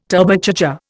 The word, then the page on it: Turbocharger: Indian